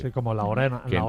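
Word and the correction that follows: Ia Orana: Y Orana es el saludo. – Y Orana. Ah, lo hemos pronunciado mal. – Ah, no, que el problema de la I cuando lo escribe, la I mayúscula... – Claro. – Es una L, ¿no? – Yo lo he interpretado como una L.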